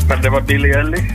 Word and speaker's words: Billie Eilish: Billie Eilish, sa du Billie Eilish. Vi är godkänt för det. – Vi är godkänt. Ja. Uttal ska inte vara det som fäller någon här. – Nej, det var alldeles korrekt med andra ord.